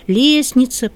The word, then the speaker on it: лестница: в отличие от таких слов, как «лестница», «праздник», где мы «т», «д» не произносим, а все-таки пишем.